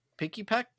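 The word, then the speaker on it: Pikipek: And then the last one of the month is Pekipek. No? I said it wrong? Picky Peck? – There you go. Picky Peck.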